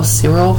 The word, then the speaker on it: Cyril: It's like in Oklahoma, it's like C-Y-R-I-L, Cyril? – Cyril.